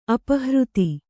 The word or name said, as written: ಅಪಹೃತೆ